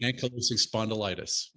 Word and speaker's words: ankylosing spondylitis: Ankylosing spondylitis.